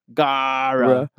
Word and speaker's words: Gaara: Gaara. Double A. Gaara.